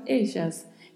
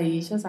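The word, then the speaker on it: ellas: Mais uma vez, atenção com a pronúncia do duplo L. Assim como comentei em milhas, mídias, aqui também vale para eixas a mesma pronúncia. Pode ter som de LH, elhas, pode ter som de DJ, elhas, pode ter som de X, eixas. Então, depende da região.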